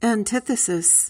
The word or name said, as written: antithesis